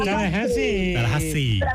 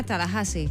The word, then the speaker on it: Tallahassee: Tallahassee.